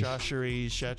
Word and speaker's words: Chachere: Oh, the Sachetori. – Chachere.